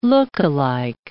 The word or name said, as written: look-alike